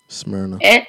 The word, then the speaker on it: Smyrna: Smyrna. – Smyrna. I was like, what were you going to say? Smyrna? – Smyrna. – You going to say Smyrna? Smyrna. Smyrna.